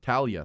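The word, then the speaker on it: Taulia: How do you say Tagovailoa's first name? – Talia.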